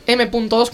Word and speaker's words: M.2: La primera parte se dice M2 y la segunda parte se dice PCI Express. Por favor. – Perfecto. Gracias. Ahí os lo presento. Gracias. – La M2